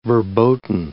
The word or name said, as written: verboten